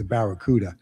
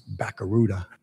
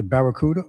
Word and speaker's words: Barracuda: So I had a flashback to my younger days when the vehicle, the automobile, came out and it was the Barracuda. And we used to pronounce it Bacaruda.